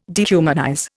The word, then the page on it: dehumanize: Indian